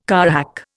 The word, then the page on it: carrack: Indian